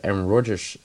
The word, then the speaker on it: Aaron Rodgers: Ehm, dan werd gisteren ook voor mij werd bekendgemaakt dat Aaron Rodgers MVP is geweest van het reguliere seizoen.